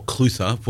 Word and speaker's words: Clutha: And uh, you've got a town called Clyde up at the top of the river, what you call Clutha, what in Gaelic would be Clua.